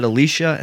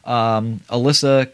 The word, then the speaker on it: Alyssa: I know some people pronounce it Alicia and some people pronounce it Alyssa. She has it spelled Alyssa, so Alyssa Kempinski plays Carol.